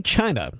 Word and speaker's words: Chynna: So let's talk to Mackenzie Phillips' sister, whose name is, I would say, China. It is spelled C-H-Y-N-N-A, some hippie.